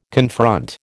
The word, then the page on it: confront: American English